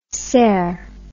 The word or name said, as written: sair